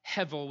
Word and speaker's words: hevel: It's the Hebrew word hevel, H-E-B-E-L, but it's pronounced hevel with a V.